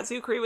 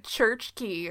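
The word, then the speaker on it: Czuchry: Yeah, the C is silent. – Oh. I was always saying, like, church key or something.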